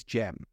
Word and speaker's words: Jem: Now, my name is Jem, and that is spelt J-E-M.